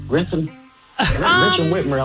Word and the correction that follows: Gretchen Whitmer: I'll let it slide because we got two tickets and I'm a hook up Sarah regardless, but it's Gretchen Whitmer.